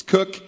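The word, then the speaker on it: Koch: There was a young man last night who knows this family, so he's like, I was pronouncing the name Co, because it's K-O-C-H, and I know a family pronounced that way. He's like, nope, it's Cook.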